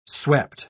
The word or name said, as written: swept